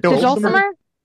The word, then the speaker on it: dulcimer: The dulcimer.